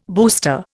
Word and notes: booster: Indian

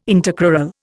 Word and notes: intercrural: Indian

